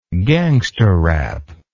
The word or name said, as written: gangsta rap